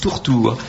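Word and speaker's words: turtur: Oui, excusez-moi, on a la certitude que les latins prononcent bien où, parce que la tourterelle, en latin, se dit, s'écrit et se prononce tourtour. Or, si vous l'écrivez en français, c'est turture.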